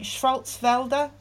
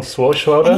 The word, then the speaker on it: Swartzwelder: And I read this fantastic quote from, I'm not going to be able to say his name, Schwarzwelder. He wrote 59 episodes of The Simpsons. – Okay. – He's a comedy writer. – John Swartzwelder? – And he says something up.